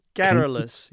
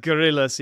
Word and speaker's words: garrulous: Stressed though as well, because there's gorillas and then there's garrulous. – I said gorillas. Yeah. Okay. So I put the stress, ladies and gentlemen. I humbly apologize for that. I put the stress on the wrong syllable.